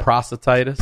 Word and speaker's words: prostatitis: Prostatitis? – Prostatitis.